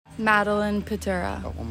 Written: Maddy Pytura

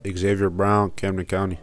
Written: Xavier Brown

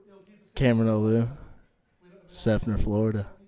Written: Cameron Oliu

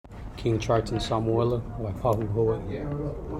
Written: Kingtriton Samuela

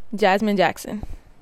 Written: Jazmyne Jackson

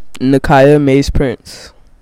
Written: Nakiyah Mays-Prince

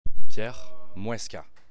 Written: Pierre Mouesca

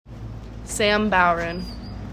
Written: Sam Bowron

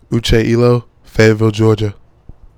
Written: Uche Iloh

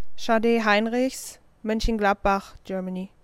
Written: Sade Heinrichs

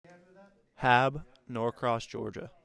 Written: Daniel Haab

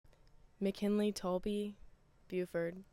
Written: McKinlee Taulbee

